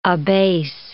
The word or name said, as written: abase